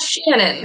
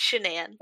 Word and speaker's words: Shanann: It's not Shannon, it's Shanann. – Okay. – Yeah. – Yeah, because it's spelled funny, so maybe they spelled it that way to try to encourage the Shanann pronunciation. – Yes, it is spelled S-H-A-N-A-N-N. And there's apostrophe between the S-H-A-N and the A-N-N.